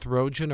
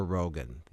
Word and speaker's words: Rogen: By the way, in addition to what we've discussed so far about the shootings, stabbing, film director Judd Apatow, and does he pronounce it Seth Rogen or Rogan? Yeah, Rogan Harji.